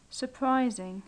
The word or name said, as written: suprising